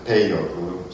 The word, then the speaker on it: peyote: And this, again, was called peyote, and some people say peyote, but it really is accented peyote. Sagan spelled it P-E-I-O-T-L, which would be pronounced peyote.